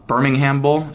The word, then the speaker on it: Birmingham: Memphis is going to come in there and just absolutely destroy them in the Jared Bingham Bowl. Birmingham Bowl. Sorry for pronouncing that the wrong way.